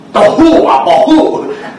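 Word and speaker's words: tohu wa-bohu: Tohu wa Bohu. It rhymes with Taufu.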